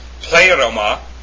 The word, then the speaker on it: pleroma: Pleroma. It's not pleroma. It's pleroma. That's how you pronounce the correct noun form of fullness.